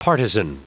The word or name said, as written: partisan